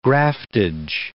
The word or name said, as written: graftage